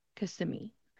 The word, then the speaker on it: Kissimmee: So it becomes K-S-I-M-M-E, Kissimmee.